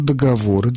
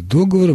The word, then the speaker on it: договоры: Договор, договоры, договоров. И дальше через двойную косую черту написано «в разговорной речи возможно». И дальше следуют варианты договора, множественное число договора, договоров.